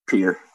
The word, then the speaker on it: Pierre: Pierre. Just the like a pier, like a water pier kind of. – That's fucking stupid. I'm going to call it Pierre.